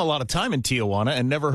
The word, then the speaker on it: Tijuana: You say Tijuana. You put an extra sound before the wana. It is a three-syllable word, which you're pronouncing as four syllables.